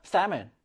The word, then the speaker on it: salmon: 자, 중간에 나오는 L은 발음이 되지 않아요, 여러분들. 묵음이에요. salmon.